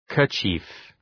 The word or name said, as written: kerchief